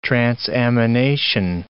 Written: transamination

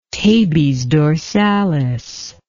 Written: tabes dorsalis

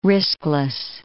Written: riskless

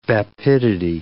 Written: vapidity

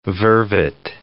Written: vervet

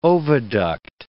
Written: oviduct

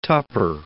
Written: Tupper

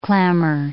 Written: clamour

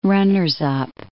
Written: runners-up

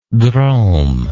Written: drome

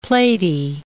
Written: platy

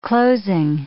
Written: closing

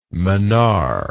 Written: mannar

